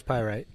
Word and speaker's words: Pyrite: So the color is Pyrite, okay? Did I say that right? – Yeah, color's Pyrite.